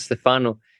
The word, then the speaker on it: Stefano: I realized this morning that I've been pronouncing your name wrong for a long time. Stefano, not Stefano. – Stefano. Yeah, it is Stefano, but sometimes when I talk in English, or when I call myself old Stefano, it is like a joke.